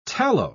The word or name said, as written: tallow